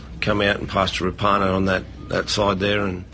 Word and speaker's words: Rapana: We've learned in recent weeks that the actual correct pronunciation is Jordan Rapana.